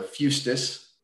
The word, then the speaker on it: fustis: So fustis, I believe is how it's pronounced, is Latin for club.